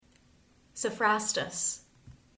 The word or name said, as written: Sophrastus